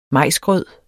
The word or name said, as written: majsgrød